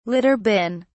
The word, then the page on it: litter bin: British English